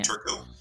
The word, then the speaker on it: Turco: It's Anthony Turco. Turco is spelled T-U-R-C-O.